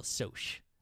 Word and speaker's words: Socs: So, how do you think you'd spell soch? – S-O... If it's short for socialites, it'd be S-O-C-S, but that's socks. – Yeah, but that's how they say it. That's the Socs.